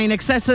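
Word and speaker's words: INXS: Allora, il nome della famosa band australiana In Excess si pronuncia appunto come la parola inglese in eccesso, in excess,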